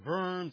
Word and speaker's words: Worms: the Diet of Worms or Worms, as it's pronounced in Germany,